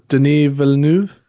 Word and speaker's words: Denis Villeneuve: So the first one at number four is, and I can never say his name, Denis Villeneuve. Is that right? Close enough? – You nailed it.